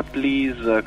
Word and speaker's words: PLZ: Allora, ti racconto di noi perché in realtà dietro questa sigla PLZ si pronuncia Please come per favore, siamo in due, non posso rivelarti le nostre identità segretissime.